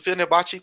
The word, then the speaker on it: Fenerbahçe: Aí temos o Fernebach contra o BB Erzurumbuspor. Perdão se eu falei errado. – Que isso. – É, mas o Fenerbahçe começando a 1h44.